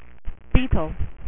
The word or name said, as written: Beetle